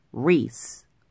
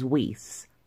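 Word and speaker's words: Reiss: It is Mike Reese with an R, not with a W. I knew that. In all of my notes and in scripting, I did write Mike Reese. But for some reason, I pronounced it as Weiss every single time without fail. I just needed to issue that correction before we go on. It is Mike Reese.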